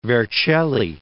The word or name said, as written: Vercelli